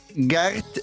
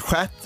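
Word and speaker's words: Geert: Eh, gert uttalas det, eh, men det uttalas skärt då.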